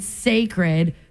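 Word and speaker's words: Cécred: She's calling it Sacred, pronounced, it's like sacred, like the word sacred, but it's actually spelled C-E-C-R-E-D. It's a play on the last syllable of her word, Beyonce.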